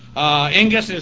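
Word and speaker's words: Ingus (ἐγγύς): Ingus is the adjective here, or adverb, pardon me, E-N-G-U-S. Again, it's actually two gammas, but by rules of Greek pronunciation, the first one's pronounced as an N.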